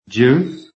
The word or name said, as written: JUICE